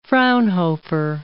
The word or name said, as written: Fraunhofer